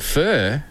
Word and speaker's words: pho: Pho? – Pho. Does that help? – Pho. – Pho. – Pho. Oh, yeah, I'm thinking like fur off the back. – Yeah, that's how you're supposed to pronounce it.